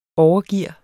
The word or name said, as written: overgear